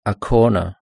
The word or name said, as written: a corner